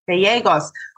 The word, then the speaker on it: Gallegos: It's a Spanish name, and so just going forward, when you see two L's together in Spanish, it's always pronounced as a Y. – Yes. – So, Gallegos. – Gallegos.